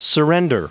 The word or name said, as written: surrender